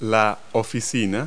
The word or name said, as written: office